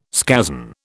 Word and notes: scazon: British English

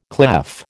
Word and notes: clef: American English

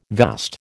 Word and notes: vast: American English

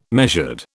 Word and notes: measured: British English

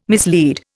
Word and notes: mislead: Indian

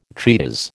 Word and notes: treatise: American English

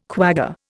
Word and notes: quagga: Indian

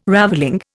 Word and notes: ravelling: Indian

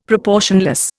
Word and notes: proportionless: Indian

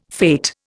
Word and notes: fate: Indian

